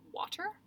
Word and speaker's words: water: It rhymes with daughter, water, daughter, water. And then I was thinking about it, and I was like, man, I think, like, British people say water, like, water, water, with a water. – T. Water. Yeah, like, with a T, but Americans say, like, with a D.